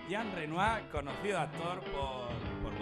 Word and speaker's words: Renoir: Jean Renoir.